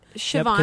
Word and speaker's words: Siobhan: I like Siobhan. – Siobhan. – Siobhan.